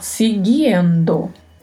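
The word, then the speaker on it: siguiendo: Muito cuidado com a pronúncia para que este i não fique escondido na sua fala, né? não fique suprimido. Então temos que falar seguindo. Eu que é o artigo masculino no singular, o artigo o.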